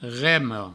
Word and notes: rema: remo

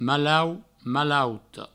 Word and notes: malaut: malauta; malauto

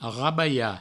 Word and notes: rabalhar: provençal; rabaia

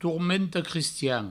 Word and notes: tormenta-crestian: tourmento-crestian

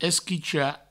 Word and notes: esquichar: provençal; esquicha

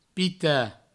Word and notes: pitar: pita

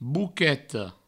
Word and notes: boqueta: Provençal; bouqueto

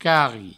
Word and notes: carri: provençal

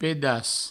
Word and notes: pedaç: pedas; provencal

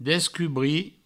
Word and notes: descubrir: Provençal; descubri

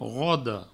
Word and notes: roda: rodo; Provençal

